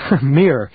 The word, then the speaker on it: Mir: Mir. M-I-R is pronounced Mir. Now, wait. What is that, Mir, M-I-R?